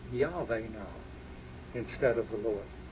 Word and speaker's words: Yahweh: I want you to note here that this is pronounced Yahweh.